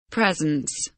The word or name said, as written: presence